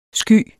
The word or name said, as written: skyet